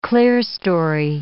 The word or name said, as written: clearstory